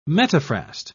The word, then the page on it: metaphrast: British English